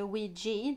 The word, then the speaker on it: Ouija: Ja, och som ni antagligen har sett på titeln på detta avsnitt så har jag ju valt då att prata om Ouija. – Mm. – Eller Ouija, det får man säga vilket som man vill. Båda uttalen finnes.